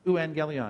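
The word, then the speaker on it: euangelion (εὐαγγέλιον): Euangelion. Have you heard that word? Euangelion, it's transliterated into English words like evangelistic, evangelism, evangelical. Euangelion. That's how you say it in the Greek language.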